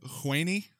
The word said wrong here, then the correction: Huney: It's Huney. – Huney? Cool.